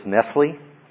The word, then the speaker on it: Nestle: This particular one is the what's called the Nestle-Aland, and some people pronounce this Nestle, because it's the same as your Nestle's chocolate. Okay, but anyway, I see it pronounced both ways.